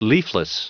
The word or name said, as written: leafless